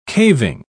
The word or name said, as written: caving